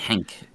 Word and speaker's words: Henk: I knew it from reading articles in Retro Gamer magazine, reading interviews with, Hank? H-E-N-K? – Yeah, they say Hank? It Hank. They it say sounds like Hank. – Yeah, it sounds like Hank, but it's spelled H-E-N-K.